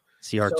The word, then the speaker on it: Isaac Szijjarto: My surname is Ciarto. – Ciarto. So okay. It's, and you got the pronunciation right.